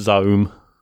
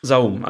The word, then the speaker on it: Zaum: Now, how do I pronounce this? – It's just Zaum. – Zaum.